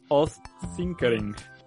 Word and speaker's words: Odd Tinkering: Es un canal que encontré en YouTube que se llama Odd Tinkering... Eh, se escribe ODD, o sea, como OWD, espacio, tin, K de kilo, ering, como Odd Tinkering.